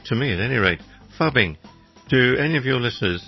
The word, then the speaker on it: phubbing: Fubbing. This is spelled P-H-U-B-B-I-N-G. Fubbing.